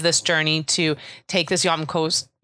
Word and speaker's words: Hjemkomst: It's spelled H. I should say, since this is a podcast, I should spell H-J-E-M-K-O-M-S-T. – It's awesome. I think I love the way it's spelled and that it sounds nothing like it when you say it.